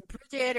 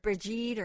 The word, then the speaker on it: Bridget: It's Bridget or Brigitte or Bride. It's said many different ways, but Bridget most commonly, I think.